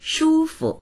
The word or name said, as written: shu1fu